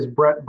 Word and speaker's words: Brett: My name is Brett Burris. That's spelled B-R-E-T-T-B-U-R-E-S, and I'm the Development Services Manager for Community Planning and Economic Development.